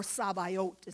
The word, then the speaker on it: Sabaoth: But even the man who has walked with God longer and more than anyone did not know Yahweh Sabaoth, or Sabaoth is how it's pronounced.